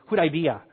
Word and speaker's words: Hudaybiyah: You got to say it a little bit faster to sound good. Hudaybiyah. Okay? Okay, so if you say it fast, then you sound like you're saying it right.